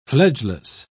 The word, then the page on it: fledgeless: British English